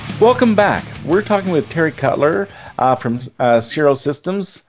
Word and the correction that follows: Sirco: Did I just say that right, Terry? Circo, S-I-R-C-O.